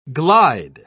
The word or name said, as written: glide